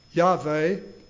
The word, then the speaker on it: Yahweh: All caps in the Scripture, in the Old Testament, in you, when you see Lord, all caps, L-O-R-D, that's referring to Yahweh. Some people say Yahweh. They don't pronounce the W like we do. Yahweh.